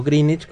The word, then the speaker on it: Greenwich: O Greenwich, como es la pronunciación.